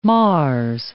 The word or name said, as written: Mars